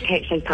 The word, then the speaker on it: Kate Chopin: It is Kate Chopin.